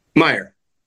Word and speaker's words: Mayer: He is playing Mayor Lehman and Mayor. Let's see, you do say it's Mayor, right? – Meyer. – It is Meyer.